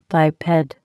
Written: biped

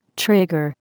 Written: trigger

